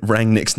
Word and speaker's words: Rangnick: You've been saying Rangnick's name wrong.